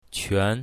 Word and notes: quan2: mandarin

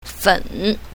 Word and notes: fen3: mandarin

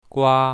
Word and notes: gua1: mandarin

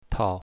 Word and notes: tao5: mandarin

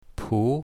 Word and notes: pu2: mandarin

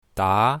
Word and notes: da2: mandarin

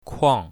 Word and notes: kuang4: mandarin

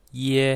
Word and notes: ye1: mandarin

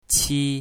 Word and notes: qi1: mandarin

